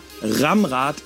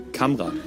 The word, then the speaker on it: Kamrad: Warum auch immer, Ramrad ist nicht der Name. Hier, Kamrad, ausgesprochen Kamrad.